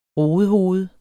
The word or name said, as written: rodehoved